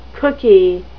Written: cookie

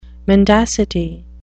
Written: mendacity

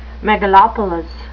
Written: megalopolis

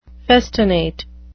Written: festinate

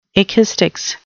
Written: ekistics

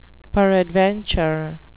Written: peradventure

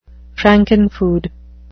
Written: frankenfood

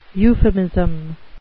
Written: euphemism

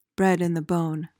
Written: bred-in-the-bone

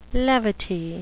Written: levity

